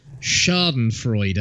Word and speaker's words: Schadenfreude: I'm not a fan of Schadenfreude. I think I said that right.